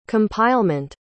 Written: compilement

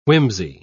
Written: whimsy

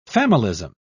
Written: familism

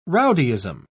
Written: rowdyism

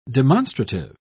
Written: demonstrative

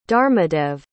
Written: dharmadev

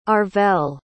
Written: Arvel

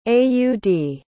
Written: aud

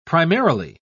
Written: primarily

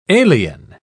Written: alien

